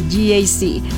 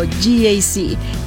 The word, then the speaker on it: GAC: Se escribe GAC, pero se pronuncia GAC. Repítelo, GAC.